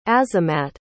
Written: azamat

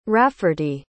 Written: rafferty

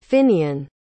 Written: Finnian

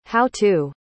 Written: how-to

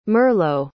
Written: merlow

